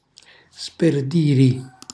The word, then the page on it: sperdiri: Calabrese